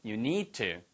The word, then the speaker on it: need to: T하고 D가 똑같은 데서 나는 소리예요. 그래서 you need to 이게 아니고 you need to. you need to.